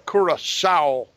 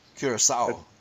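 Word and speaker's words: Curaçao: It's Curacao. That letter is an S. – Curacao. – It's pronounced by like an S, yeah. – Okay. – When so you have a little tail underneath the C.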